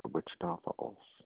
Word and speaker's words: Wichita Falls: Yeah, Wichita Falls.